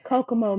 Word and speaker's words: Kokomo: You were from, and help me with the pronunciation, Kokomo, Mississippi, correct? – That is correct. Kokomo, Mississippi.